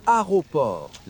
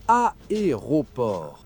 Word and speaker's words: aéroport: Beaucoup de gens, y compris des natifs, avalent le premier « e » et disent « aéroport ». La bonne prononciation, « aéroport ». Trois syllabes distinctes au début et le « t » final est muet.